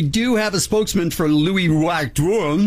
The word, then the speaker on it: Louis Vuitton: I don't know you're pronouncing it right. Let's let our friend from CNN, Jenny, pronounce it for us. – This Louis Vuitton airplane bag sells... – There you go. That's how you say it.